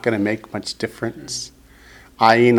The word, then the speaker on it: ayin: The next word accents the last syllable, ayin.